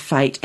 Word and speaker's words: fete: It's a very popular Christmas fete. Am I saying that right? – Fete, yes.